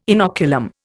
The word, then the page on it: inoculum: Indian